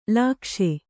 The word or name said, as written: ಲೋಕೇಶ